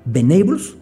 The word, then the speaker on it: Venables: Si usted la quiere ver, google Ben Abels, se escribe Venables Guardiola,